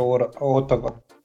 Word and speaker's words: Оттава: Или, подожди, как правильно говорить, Отова или Отава? – Отава. я у тебя все хочу спросить, почему ты ударение на первую букву ставишь? А потому что по-английски это Оттава.